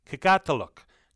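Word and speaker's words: Qikiqtaaluk: I said I couldn't pronounce the first name of the Fisheries Corporation. I couldn't until I just heard from the GM, Joel Hickey. It's Kikatiluk. Kikatiluk Fisheries, and they're at 1121 Kenmount Road in Paradise, just past Robin's Donuts.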